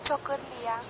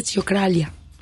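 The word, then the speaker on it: Ciocărlia: Fanfara Chocorlía. – Muchas gracias. Así se llama, Fanfara Chocorlía. – Yo, lo, yo nada más de verlo hubiera dicho Fanfara Chocralia. – Sí, no. – Como que así se lee.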